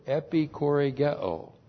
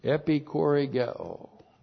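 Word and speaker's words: epikoregeo (ἐπιχορηγέω): And the word there was a very special word, epikoregeo. Epikoregeo. Looks like that in the Greek. That's E-P-I-C-H-O-R, long E-G-E, long O.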